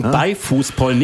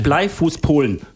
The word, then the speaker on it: Beifußpollen: Und nochmal Betonung, Beifußpollen, nicht Bleifußpollen. Okay? – Ja, richtig.